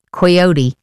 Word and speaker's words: coyote: And switching gears completely, Coyote versus Acme. Do you say coyote? – We say coyote. – Coyote? – Yeah. – Even though it's spelt with an O.